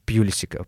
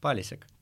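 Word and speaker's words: Pulisic: Я, я за пять минут три раза по-разному назвал Пьюлисика. Прикиньте, пацаны, просто я еще удивился, что на Евроспорте он Палисик. – Да, именно. Охренеть Не просто. Не забывай, как правильно произносится эта фамилия. Пьюлисик. Палисик. Палисик.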